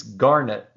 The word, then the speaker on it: Garnett: And it's only appropriate that he mispronounces or miswrites the name because – it's Garnett, like the stone.